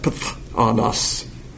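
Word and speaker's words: Pthanos (φθόνος): So it's pronounced Pthanos, which means jealousy.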